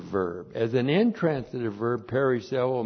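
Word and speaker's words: perisseuo: Periseuo, P-E-R-I-S-S-E-U, long O. Periseuo.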